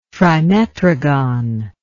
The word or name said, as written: trimetrogon